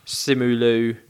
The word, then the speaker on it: Simu Liu: I think Trevor Slattery brings a lot of it, but just like the little bits between Awkwafina and Simu Liu.